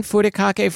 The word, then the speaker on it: furikake: And that's because this week we're talking about furikake.